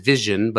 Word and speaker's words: Veesion: They pronounce it Veesion, but it's spelled V-E-E-S-I-O-N.